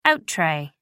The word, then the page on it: out-tray: American English